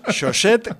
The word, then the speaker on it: chaussette: Tal como lo has pronunciado, parece otra cosa. – Bueno, pues chaussette, que sepáis que no es otra cosa, sino calcetines en francés, ¿vale?